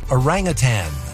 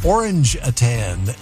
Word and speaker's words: orangutan: Rita says the word is orangutan. I pronounced it orange-a-tan and inquired as to what it was.